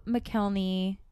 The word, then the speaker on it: McElhenney: It features Ryan Reynolds and Rob McElhenney. I don't think I'm saying that right. McElhenney? – McElhenney. I mean, you're doing your best.